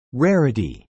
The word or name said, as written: rarity